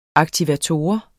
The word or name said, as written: aktivator